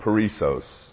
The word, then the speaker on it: perissos: The word abundant in Greek is the word perissos. It's spelled P-E-R-I-S-S-O-S. Perissos.